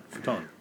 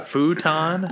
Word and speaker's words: futon: You call it a futon? – Wait, I'm saying it incorrectly? – Yes. – Futon. – Yes, that's wrong. – What? – It's futon. It's Japanese.